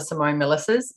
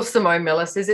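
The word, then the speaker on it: Simone Milasas: And so far I haven't discovered another Simone Millises. So if you Google Simone Millises, it's my Instagram handle, Facebook, website, all of that. And it's S-I-M-O-N-E-M-I-L-A-S-A-S.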